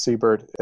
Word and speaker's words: Seibert: I'm almost positive it's pronounced Siebert. It's Eric with a C, Siebert, S-E-I-B-E-R-T.